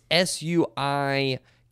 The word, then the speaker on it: Suikoden: It's Suikoden.